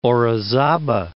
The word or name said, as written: Orizaba